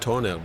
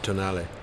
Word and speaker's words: Tonale: It's spelled toenail, but it's actually tonale.